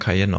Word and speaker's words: Cayenne: Porsche Cayenne? Porsche Cheyenne? Nee, stimmt, ja. Den spricht man Cayenne aus.